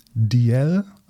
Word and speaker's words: Dalziel: June DL Almeida und das DL in der Mitte wird geschrieben D-A-L-Z-I-E-L. – E Nochmal. D-A-L? – Z-I-E-L.